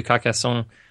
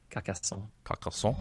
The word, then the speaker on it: Carcassonne: We moved to Carcassonne just last month. – That was a nice pronunciation. – Thank you. – Carcassonne? – I do my best. Carcassonne.